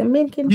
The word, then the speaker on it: Mencken: It's Mencken. Am I saying it right? Is it Mencken? – Yeah.